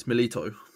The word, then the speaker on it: Milito: First of all, it's Milito. Secondly, I like to add the extra pronunciation.